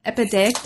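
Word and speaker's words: epideictic: So this chapter focuses on a word I'm going to mispronounce, and um, you can pronounce epidectic. Yep, that's that's, that's, how I pronounce it.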